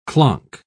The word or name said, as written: clonk